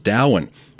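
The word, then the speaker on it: Domhain: And to finish off the triplet of world tripping, we had, I was initially going to pronounce it Dom Hain because I am not from Ireland, but supposedly you looked that one up and it's pronounced Dowan, kind of like Samhain, which I would have pronounced Samhain, because again, uncultured Italian-Austrian man right here.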